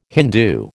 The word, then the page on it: Hindu: American English